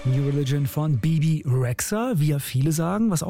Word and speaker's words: Bebe Rexha: aber eigentlich so richtig auf Albanisch wird sie Bebe Rexha ausgesprochen. Als wäre da noch ein D irgendwo, aber gut, wieder was dazugelernt.